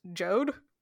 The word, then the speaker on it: Jeod: But it is called the Beor Mountains, which is fine, except it's spelled B-E-O-R, Beor. But we also have J-E-O-D, which is pronounced Jode.